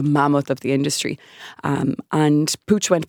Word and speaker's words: Puig: Yes, PUIG, Pooch. No one knows how to say it or spell it. It's a Catalan, so they're a family from Barcelona, an incredibly entrepreneurial family, over 100 years in business.